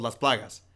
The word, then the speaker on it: Las Plagas: They're called Las Plagas. I speak and Spanish, that's why I can say it properly.